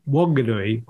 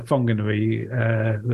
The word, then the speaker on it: Whanganui: Wairika Road in Whanganui or Whanganui, they spell it without the H. – Either is correct, apparently.